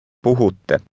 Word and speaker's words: puhutte: Puhutte. – Pay close attention to the double T sound at the end.